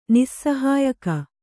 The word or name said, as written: ನಿಸ್ಸಹಾಯಕ